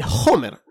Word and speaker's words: Homer: Ah, Pero no, acá dicen Homer. Con, o sea que pronuncian la H. Que en español no se pronuncia.